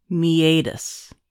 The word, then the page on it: meatus: American English